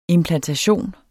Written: implantation

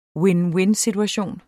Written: vind-vind-situation

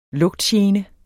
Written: lugtgene

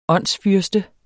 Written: åndsfyrste

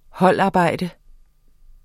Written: holdarbejde

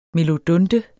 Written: melodunte

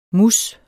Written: mousse

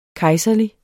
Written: kejserlig

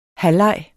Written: halvleg

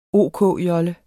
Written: OK-jolle

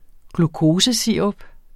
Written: glukosesirup

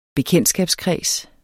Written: bekendtskabskreds

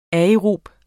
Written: aerobe

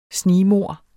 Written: snigmord

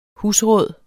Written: husråd

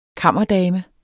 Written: kammerdame

